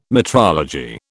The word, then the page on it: metrology: British English